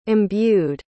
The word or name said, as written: imbued